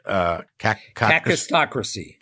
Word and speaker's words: kakistocracy: Kakistocracy.